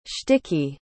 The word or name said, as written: shticky